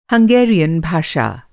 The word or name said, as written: हंगेरियन